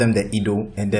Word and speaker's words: Edo: How did you pronounce it? Is it, you said Edo? – Yeah, Edo.